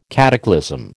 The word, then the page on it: cataclysm: American English